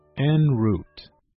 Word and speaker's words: en route: 天我们要学的词是 en route. N is spelled E-N. N and root. R-O-U-T-E. Root. En route.